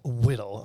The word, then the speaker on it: whittle: I like how you say it, whittle. – I know, it's sexy, isn't it? – Pronounced H in there.